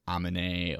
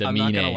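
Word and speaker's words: Aminé: He like made an entire song on his debut album on like how people mispronounce his artist name as like Amine or Anime or Anumina. Like, it's dumb. It's Amine.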